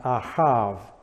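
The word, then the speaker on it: ahav (אהב): And the first one is ahav, aleph, hey, bait. And ahav, the B has a soft V pronunciation, and ahav, meaning to love or like in all cases.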